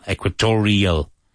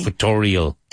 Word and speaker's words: Equatorial: Now make sure you pronounce the L. Equatorial. Like editorial. – Okay. – Equatorial.